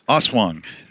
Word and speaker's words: aswang: I don't know how to pronounce. I'm just gonna say Aswang. Is that how it's pronounced? – Aswang. It's a Filipino monster that eats babies out of the mother's womb with a really, really long tongue.